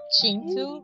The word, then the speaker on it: Kintu: So, and of course, your favorite, Kintu. – Oh! Chintu? You know, it's actually pronounced Chintu. Chintu, yes. That K is actually a ch in Ugandan.